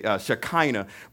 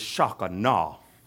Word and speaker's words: Shekinah: And a lot of people say Shekinah, but the way you really pronounce it is Shekinah.